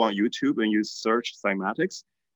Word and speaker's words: cymatics: It's spelled C-Y-M-A-T-I-C-S, oh. Cymatics.